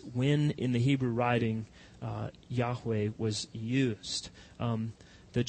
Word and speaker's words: Yahweh: No one is completely sure how it's to be pronounced, but we've pronounced it as Yahweh.